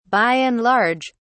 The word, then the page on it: by and large: British English